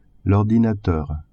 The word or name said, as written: ordinateur